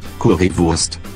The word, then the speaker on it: Currywurst: Oh, FYI, in German, a W sounds like a V. – C, currywurst, die Currywurst, die Currywurst.